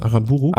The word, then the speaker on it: Aranburu: Kollegen Alex Arana, wie spricht man das? Aranburu. Ja.